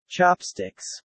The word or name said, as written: chopsticks